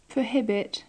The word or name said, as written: prohibit